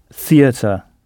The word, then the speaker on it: theater: Le mot pour théâtre est le même en anglais, mais on prononce theater, theater.